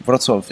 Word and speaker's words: Wrocław: Wrocław.